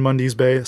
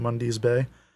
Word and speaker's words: Mundy's Bay: The band Mundy's Bay. So that's spelled MUNDY'S, mm-hmm. Mundy's Bay.